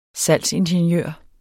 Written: salgsingeniør

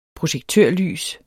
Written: projektørlys

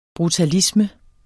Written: brutalisme

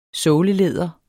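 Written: SÅLELÆDER

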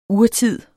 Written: urtid

